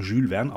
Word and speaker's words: Jules Verne: Das spricht man Jules Verne aus, du Trottel, entgegnete Werner.